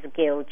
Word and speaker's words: Gill: And then my last name is Gill, G-I-L-L.